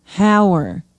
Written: hauer

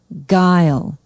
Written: Gile

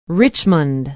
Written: richmond

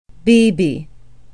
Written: beebe

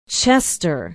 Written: chester

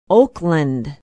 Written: oakland

